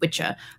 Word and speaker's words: wicce: So the original word for a woman who practices sorcery or witchcraft was spelled W-I-C-C-E, pronounced witcha.